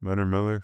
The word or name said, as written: leonard MILLER